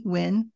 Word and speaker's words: Wynn: Like the wind without the D. Wynn.